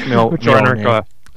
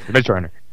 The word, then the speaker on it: Mjolnir: It's Mjolnir. – Anyway. – Mjolnir.